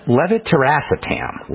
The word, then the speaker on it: levetiracetam: levetiracetam. Levetiracetam is how you say it.